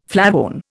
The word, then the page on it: flavone: Indian